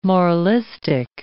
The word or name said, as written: moralistic